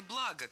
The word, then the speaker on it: блага: Во-первых, хочу исправить материальные блага на материальные блага, конечно же. Ударение в слове «благо» всегда падает на первый слог. Оно неподвижное. «Благо». Благо, благами, благом.